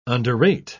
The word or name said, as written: underrate